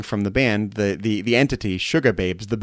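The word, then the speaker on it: Sugababes: Not sugar with an R, Suga Babes. Suga Babes.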